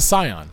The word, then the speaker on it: Scion: Skion? S-C-I-O-N. Is that a car? – It's a car, yeah. – Well, there's a dealership. – It's a Scion. – Scion, it's pronounced. My bad.